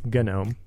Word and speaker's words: GNOME: GNOME also takes a hard G, GNOME.